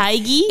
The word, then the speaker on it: Hygge: It's like, I think it's pronounced like hug or something, but it's spelled Hygge? – H-Y-G-G-E.